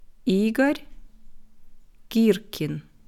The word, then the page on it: Igor Girkin: slow